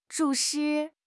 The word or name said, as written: 注视